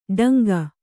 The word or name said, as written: ಡಂಗ